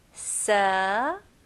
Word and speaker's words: severe: 네, 여기서는 V 발음 해주셔야 돼요. 그래서 입술이 붙으면 안 되고 제 입 모양을 보세요. Severe. Severe. 네, 여기서 스펠링은 S-E이지만 발음은 se. Severe, severe. 그리고 R 발음까지 마무리 잘 해주셔야 됩니다.